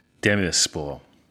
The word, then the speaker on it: Demirspor: As you're probably aware, listener, Balotelli is currently playing his football out on the kind of Turkish-Syrian border for Adana Demirspor. Have I said that right? – Demirspor.